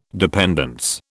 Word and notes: dependence: British English

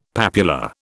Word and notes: papula: British English

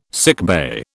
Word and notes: sickbay: British English

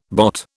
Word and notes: bot: British English

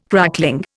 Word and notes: crackling: Indian

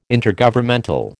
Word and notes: Intergovernmental: American English

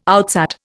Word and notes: outsat: Indian

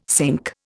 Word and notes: sink: Indian

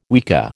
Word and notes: weka: American English

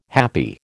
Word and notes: happi: American English

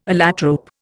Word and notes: allotrope: Indian

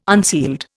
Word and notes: unsealed: Indian

